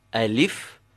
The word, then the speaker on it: alif: The first name, the Arab reciter or scholar would emphasize the first of its syllables, Alif. And they will not emphasize the second syllable, the li, alif. So practice, instead of saying alif, press the a and say alif, alif.